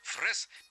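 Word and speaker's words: Fraise: Так как со старофранцузского дворянская фамилия Фрез, последняя буковка-то не читается, правильно меня поправил наш рассказчик. Фрез переводится как земляника.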